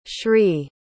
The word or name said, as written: shree